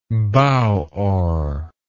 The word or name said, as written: bow oar